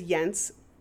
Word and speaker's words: Jens: So his name is Jens, rhymes with fence.